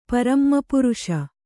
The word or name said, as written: ಪರಮಪುರುಷ